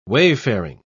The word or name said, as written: wayfaring